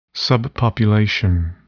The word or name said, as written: subpopulation